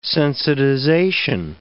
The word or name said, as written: sensitization